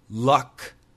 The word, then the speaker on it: luck: So never get the words luck, lock, and look confused. The sound is very different. This is L-U-C-K. Luck. Luck. Luck. That is the short U. Luck. L-O-C-K.